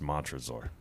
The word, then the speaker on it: Montresor: The protagonist's name is pronounced Montresor. – Montresor? – Montresor.